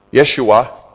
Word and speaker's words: Yeshua: And he says, and Jesus, or Yeshua, would be the way it would have been pronounced in Aramaic, Jesus, who is called Justice, these are my only fellow workers for the kingdom of God who are of the circumcision.